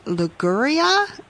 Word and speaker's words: Liguria: I also love the history, too, as we travel to, and pardon my Italian pronunciation, I'll need your help here, for classic recipes from Liguria. Is that how you say that? – You've got it perfect.